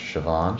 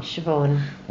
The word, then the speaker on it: Siobhan: It's pronounced Siobhan. – Siobhan. – Why? – Why? The S and the I is a sh, yeah. – And the B and the H is a v.